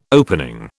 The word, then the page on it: opening: British English